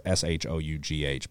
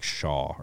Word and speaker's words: Shough: It's Shough, but they say like Shaw or some shit. – Mm-hmm. – Like Shoff.